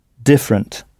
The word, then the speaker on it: different: En insistant bien sur la première syllabe, different. Et